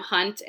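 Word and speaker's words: Hundt: Well, my Twitter handle is at KM Hunt, and that's H-U-N-D-T. I have a silent D in my name.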